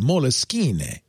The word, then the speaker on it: Moleskine: But it's Moleskine. Or as I say, Moleskine. I once asked them how you pronounce it. They say any way you want. They don't care.